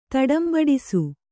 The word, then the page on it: ತಡಂಬಡಿಸು: taḍambaḍisu